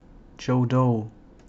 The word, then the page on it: Godot: jodou